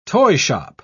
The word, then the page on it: toyshop: British English